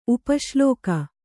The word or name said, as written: ಉಪಶ್ಲೋಕ